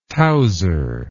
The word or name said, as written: towser